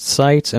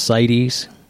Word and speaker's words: CITES: I've heard it pronounced Seitz, and I've heard it pronounced Seitz.